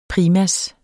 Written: PRIMASSERS

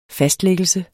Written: fastlagte

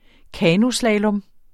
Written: kanoslalom

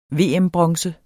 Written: VM-bronze

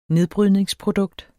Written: nedbrydningsprodukt

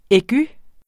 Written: aigu

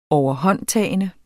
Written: overhåndtagende

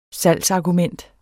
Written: salgsargument

